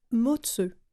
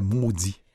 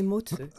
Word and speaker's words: Mozi: Et ça s'écrit M-O-Z-I, mais le Z-I se prononce « ce ». – Ah, on apprend quelque chose. – Oui. Moi, je pensais que c'était maudit. – Ah non, c'est Motzeu.